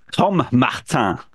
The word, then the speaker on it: Tom Martin: Today, we have with us the wonderful, and I'm going to pronounce this perfectly in French, Tom Martin. Is that right? – Spot on pronunciation, yeah.